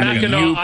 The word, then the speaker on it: Mackinac: It's Mackinac.